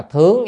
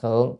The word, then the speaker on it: thướng: Bởi vì thần thông cái đó đó à, cái chữ này đọc là thướng chứ không đọc là thượng. Thướng thì mới là động từ mà đó, mình lên, đi lên. Mình đọc thượng á, là nó thành là gì? Phương vị từ mất rồi, không đúng rồi.